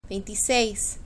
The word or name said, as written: veintiseis